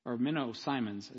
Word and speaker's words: Menno Simons: But Menno Simons, Menno Simons is how it's pronounced, I think, properly. That's how the Mennonites say it.